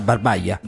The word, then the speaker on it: Barbaja: Barbagia o Barbaia? – È scritto come? – Barbagia con la J. – Quindi sarà Barbaia. – Barbaia. Che portò al teatro. Barbaja, se è di origine spagnola.